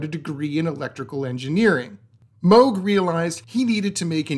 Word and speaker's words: Moog: After the first day of class, the children began to moo at her because her name was Mrs. Moog, to which Bob simply changed the way he pronounced his last name to the way many of his cousins pronounced his name, Moog.